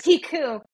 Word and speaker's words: Picoult: Picoult.